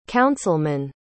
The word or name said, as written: councilmen